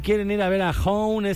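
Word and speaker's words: Honne: Si se quieren llevar un boleto doble para ver a Hone,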